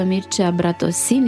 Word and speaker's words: Mircea Bratosin: Alături de mine și în această seară se află Mircea Bratosin, iată că acum îi pronunț corect numele, e bine Mircea? – E foarte bine,